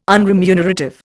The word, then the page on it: unremunerative: Indian